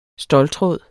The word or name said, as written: ståltråd